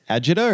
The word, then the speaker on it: agita: Agita or agita? – And, well, how agita. Do you want to say it? Agita, dude. Dragon Ball Z. – Yeah, it's pronounced agita.